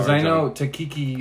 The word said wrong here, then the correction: Taika Waititi: Taika Waititi, yeah.